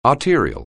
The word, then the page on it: arterial: anglais